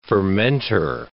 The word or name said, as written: fermenter